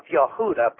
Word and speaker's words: Yehuda: or the tribe of Yehuda, properly pronounced in the Hebrew, we have used this word Jew loosely.